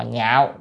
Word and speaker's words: nhạo: Chữ này âm lạc với nhạo có hai âm, có ba âm nữa, có âm thứ ba nữa là nhạc nữa, nhưng mà chúng ta nên đọc là nhạo, quý vị nên nhớ nha, chữ nhạo với lạc khác nhau nha.